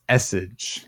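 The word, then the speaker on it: esseJ: Essage? – It's Essage. Lowercase E, capital J at the end, which, Peter, it is Jesse backwards.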